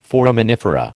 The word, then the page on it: foraminifera: American English